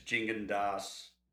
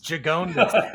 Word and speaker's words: Gigondas: I always said it wrong. I used to go like Gigondas. – Gigondas. Gigondas. It sounds like Quijones or something.